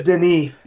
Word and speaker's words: Denis: Because I don't even think his first name is pronounced Dennis. I think that's how we say it. – It's Denis. – It looks like Dennis, but he actually, in his language, it sounds completely different.